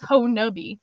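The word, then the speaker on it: Honobia: oh, it's Honubi, and they're like, well, there's like 10 different ways to pronounce it, and I was like, not if you're from there.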